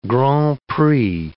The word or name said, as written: grand prix